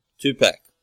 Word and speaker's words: Tupac: Tupac. – T-U-P-A-C.